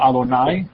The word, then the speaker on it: Adonai: Después vimos también que la palabra sagrada hebrea, que es JHWH, que no se pronunciaba en el Antiguo Testamento, WH, se pronunciaba como Adonai, ¿verdad?